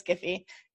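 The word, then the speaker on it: GIF: I'm so happy to hear you use the hard G. – Right. Sorry. Jiffies. – No. Matt Cromwell and I have this long going thing where he says it's Jiffy and I say it's Giphy, and I'm right.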